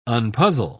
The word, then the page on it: unpuzzle: British English